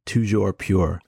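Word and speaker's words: toujours pur: What is the French pronunciation? We've been waiting. – Oh, it's toujours pure.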